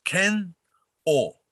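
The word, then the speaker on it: Kenoh: Kenho, people in English speaking world, you know, this hard to pronounce. They always say Ken-O, right? – Mm-hmm. – It's Ken-O.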